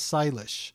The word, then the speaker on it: Sailesh: In the English-speaking world, I like my name to be pronounced as Sailesh. I know that can be hard to pronounce, so let me give you a little hint. Sailesh also rhymes with stylish. So Sailesh is stylish without the T.